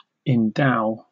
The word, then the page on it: endow: British English